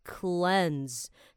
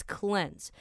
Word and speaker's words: cleanse: Now this word, remember, is pronounced as cleanse. 它拼出来是 C L E A N S E，看起来像是 cleans, but no, you gotta say it as cleanse.